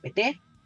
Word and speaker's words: PT: Eh, hemos hablado de Lula, ahora si te parece hablamos de la estructura de partido, hablamos de, del PT, nadie confunda la sílaba tónica para los argentinos, em, porque evidentemente sabemos que en política no existen peces eh, solos, ¿no?